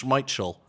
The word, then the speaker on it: Schmeichel: Especially because we've been getting Schmeichel wrong for like 25 years, Roger. It's Schmeichel.